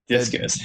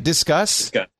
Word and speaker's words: Disqus: Discuss? – Yep. – Oh, not like discus, like the discus throwing, but discuss like discussions.